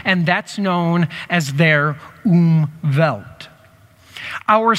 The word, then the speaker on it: Umwelt: It's called its Umwelt, U-M-W-E-L-T, but it's a German word, so it's pronounced Umwelt. Go ahead and say Umwelt.